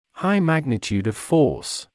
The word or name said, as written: high magnitude of force